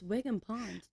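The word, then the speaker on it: Wigwam Pond: Like Wiggum Pond? – No, that's Wigwam. – No, it's Wiggum Pond near me. – But it's spelled Wigwam.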